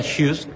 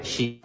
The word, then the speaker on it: Jysk: Se pronuncia Jysk, se escribe Jysk.